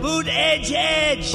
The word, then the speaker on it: Buttigieg: They're saying how to say his name, Buttigieg.